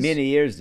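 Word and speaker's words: Ménière's: Someone pointed out that basically what they're describing is, and I don't know how to pronounce this, it's called Meniere's disease. – Meniere's disease, yeah.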